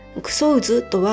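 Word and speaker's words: 草生津: 九相津とは草に生きる津と書いて九相津と読むのですが、語源になったのは臭い水。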